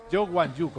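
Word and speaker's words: Zhou Wanyu: Hay que ser muy certero el nombre, ya que lo dice que tienes interés en ellos, es Joe Wan Yu, como se dice. – Y se escribe... – Se escribe Wan Yu Zhou.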